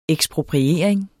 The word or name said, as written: ekspropriering